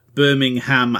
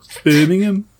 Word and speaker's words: Birmingham: Or if Americans were to pronounce it, like they pronounce Birmingham, Alabama, as opposed to Birmingham.